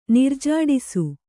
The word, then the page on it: ನಿರ್ಜಾಡಿಸು: nirjāḍisu